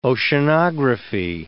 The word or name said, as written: oceanography